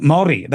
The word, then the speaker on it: Māori: And now they're going after the young Māori. That's the correct pronunciation.